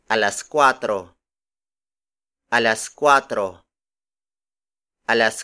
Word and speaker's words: kuwatro: The next number is cuatro. Cuatro. Cuatro. Cuatro is spelled as K-U-W-A-T-R-O.